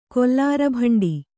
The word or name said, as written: ಕೊಲ್ಲಾರಬಂಡಿ